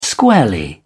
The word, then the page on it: squarely: British English